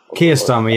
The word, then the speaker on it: Keir Starmer: So, Keir Starmer, yeah, that's how you pronounce it.